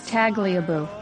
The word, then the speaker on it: Tagliabue: So this first word is Tallybaloo? – Try that just one more time. – Tagliabue.